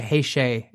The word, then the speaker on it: Heisei: I, I think it's like a Heisei is the pronunciation, and then you're right. Yeah, that is.